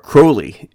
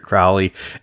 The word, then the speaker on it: Crowley: Of course, Ozzy pronounces it wrong. It's actually Aleister Crowley is how it's actually pronounced, but I've always heard it Crowley, and so I'm going to say Crowley.